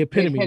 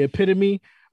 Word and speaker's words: epitome: the epitome? Am I saying it right? – Oh, the epitome. – The epitome, my bad. The epitome of being now I have like this independent horrible, artist.